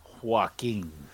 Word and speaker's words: Joaquin: How you pronounce his first name? – Joaquin. – Joaquin Phoenix? – Yeah, it's like walking.